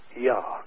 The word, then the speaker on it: Jah: The one verse in the King James translation which does bring his name over into the English in the form of Jah, pronounced Yah, is by itself enough to be the obedient person to see the responsibility in the book of Psalms 68:4.